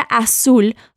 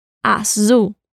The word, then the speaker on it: azul: Y sí, lo sé, la palabra azul suena diferente. La L al final suena como una U y la Z suena como una abeja.